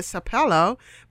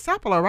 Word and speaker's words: Sapelo: and that is spelled S-A-P-E-L-O, Sapelo. Some people call it Sapelo, but the local folks call it Sapelo, right? – They call it Sapelo.